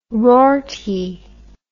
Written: rorty

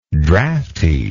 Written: draughtiness

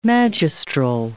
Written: magistral

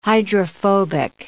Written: hydrophobic

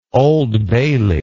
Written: Old Bailey

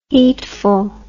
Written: heatful

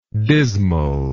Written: dismality

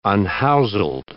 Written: unhouseled

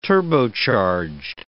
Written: turbocharged

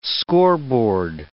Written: scoreboard

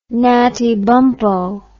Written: Natty Bumppo

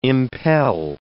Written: impel

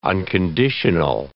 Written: unconditional

